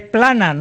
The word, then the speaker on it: Planas: Ministro Pilanas. – No, es Planas, no Pilanas.